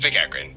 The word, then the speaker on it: McEachren: Is it Michael McEachren or McEachern? – It's McEachren.